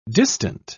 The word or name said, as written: distant